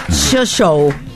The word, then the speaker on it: Shossow: Andrea Shoshou. – ¿Andrea? – S-H-O-S-S-O-W. Shoshou. – Yo creería que se dice así.